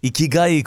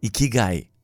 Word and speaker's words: ikigai: It's pronounced ikigai. You lift your cheeks like you're smiling.